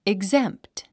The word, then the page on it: exempt: iɡzémpt; イグゼンプト